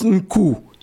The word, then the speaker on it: Nku: Et dites-moi, ce qui a piqué ma curiosité, c'est cet instrument que vous utilisez qui s'intitule Nku, qu'on prononce Nku.